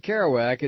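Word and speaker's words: Keroack: You pronounce it Kerouac. It's nothing to do with Jack. It's spelled a little bit different.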